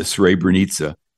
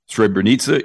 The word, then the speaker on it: Srebrenica: And by the way, Srebrenica is spelled S-R-E-B-R-E-N-I-C-A. Nothing like it is pronounced.